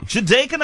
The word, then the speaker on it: Jedeikin: Have I pronounced your name right, Jedeikin? – Jedeikin, I got it right second time round.